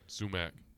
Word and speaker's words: sumac: Is that how you say it? Sumac? Sumac? – That's correct. Sumac.